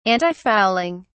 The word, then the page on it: antifouling: British English